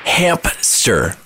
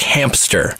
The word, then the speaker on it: hamster: I thought it was Hamp-ster. – Yes. – Like a hamper, like hamster. – Mm-hmm, with a P. – Until yesterday, 31 years into my life, I've been saying this wrong, thinking it was a hamster. – I love it. So we But it's a ham-ster? – Hamster.